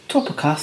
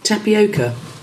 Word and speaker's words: tapioca: Topica starch. – Tapioca.